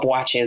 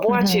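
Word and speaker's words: Buachie: It's not, the way I spell it, from what I know of, is the original spelling with the K-Y-E ending. Of course, in terms of acting, I've phoneticized it. So it's B-U-A-C-H-I-E. But Bwachi is like, it's such an Ashanti name.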